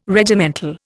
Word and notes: Regimental: Indian

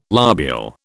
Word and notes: lobule: British English

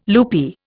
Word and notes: loopy: Indian

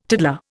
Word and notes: tiddler: Indian

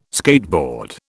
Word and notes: skateboard: British English

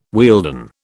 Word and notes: Wealden: British English

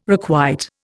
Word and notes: requite: Indian

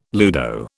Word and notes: ludo: British English

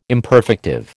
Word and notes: imperfective: American English

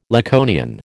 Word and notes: Laconian: American English

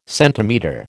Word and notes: centimetre: American English

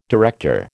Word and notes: director: American English

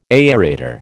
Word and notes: aerator: American English

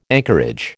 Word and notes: anchorage: American English

